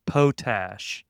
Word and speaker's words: potash: I believe that the correct, at least Texan pronunciation of that word is potash.